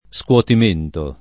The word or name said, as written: scuotimento